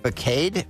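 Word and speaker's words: façade: Another word that is spelled F-A-C-A-D-E. You may think it's facade, but it's facade.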